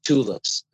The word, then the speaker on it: Txlips: uh, the tulips, but it's oh the tulips. – L, yeah, it's spelled T X L I P S.